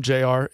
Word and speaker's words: Jayar: My name, JR, is spelled all weird. It's not J and R. It's JAYAR.